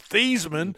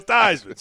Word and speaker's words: Theismann: Well, you know, Joe Theismann is, you know, the way you originally the way he pronounced his name his whole life was Theismann up until he won the Heisman.